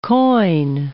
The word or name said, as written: quoin